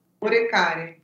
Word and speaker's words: Oricari: E é o reino de Oricari, é assim que se pronuncia? – Oricari, isso.